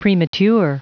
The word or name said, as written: premature